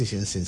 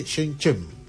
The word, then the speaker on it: Shenzhen: Y no solamente qué es Shenzhen, lo primero que me explicaron es, no se dice Shenzhen, se dice Shenzhen.